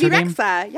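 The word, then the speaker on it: Bebe Rexha: did you say BB Rexha? – That's BB Rexha, yeah.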